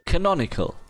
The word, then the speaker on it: Canonical: Canonical.